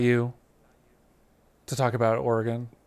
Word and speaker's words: Oregon: I reviewed Oregon and am I saying it right? I feel like there's multiple ways to say it. Nope, that's the right one.